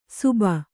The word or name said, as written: ಸುಬ